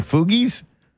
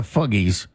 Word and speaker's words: Fugees: The Fugees? It sounds funny to me. I know it's not the Fuggies. It's the Fugees, right? Or is it Fugees? I don't know. It's spelled all weird. F-U-G-E-E-S.